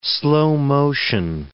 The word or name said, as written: slow-motion